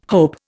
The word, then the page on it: hope: Indian